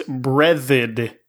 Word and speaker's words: Breathed: Is it Breathed or Breathed or I thought it was Breathed. It was Berkeley Breathed. – Breathed. – According to Wikipedia, okay. It's Breathed.